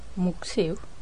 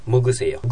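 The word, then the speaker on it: 먹으세요: 먹 세 요。 – 먹 으세 요。ぐとはっきり発音しなきゃ。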